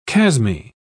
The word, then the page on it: chasmy: British English